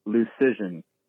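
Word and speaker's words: Luszczyszyn: And the way he put it is that it's like decision. So it's Dom Lucision.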